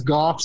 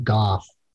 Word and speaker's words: Gough: Like, there's a street in what city has Golf Street? G-O-U-G-H. And I always thought it was Go Street, but it they do pronounce it Golf.